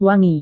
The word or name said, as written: wangi